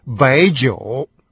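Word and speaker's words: байджоу (白酒): Это слово, как вы только что слышали, произносится тоже со вторым тоном. И, соответственно, белое вино звучит как байджоу.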